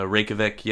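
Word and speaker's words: Reykjavík: But, hey, how big is Reykjavik? Am I saying it right? – Yeah, yeah, Reykjavik.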